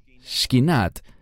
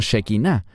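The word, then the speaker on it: Shekhinah: Y la presencia de Dios era llamada Shkinat, que algunos pronuncian como Shekinah, pero en hebreo es Shkinat Hashem.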